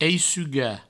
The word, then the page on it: eissugar: eissuga; Provençal